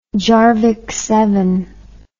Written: jarvey

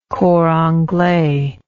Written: cor anglais

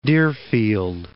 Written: Deerfield Beach

